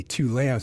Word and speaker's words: Tu Lam: And my name is pronounced Tu Thung Lam in Vietnamese, right? But I say Tu Lam, so you guys don't mess up my Vietnamese name.